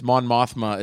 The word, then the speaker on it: Mon Mothma: Okay, Mon Mothra. – Who's Mon Mothra? – Mm. – Did I say it wrong? – Yeah, if you saw the trailers, Mon Mothma is definitely in there.